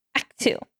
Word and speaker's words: Akitu: It was a 12-day celebration, and I'm going to say this wrong, called Akitu. A-K-I-T-U.